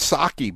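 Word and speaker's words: Psaki: Is that how you say it, Howie? I feel like I keep saying her name wrong. – Yeah, it's Saki, but I call her P-Saki.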